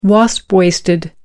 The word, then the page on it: wasp waisted: American English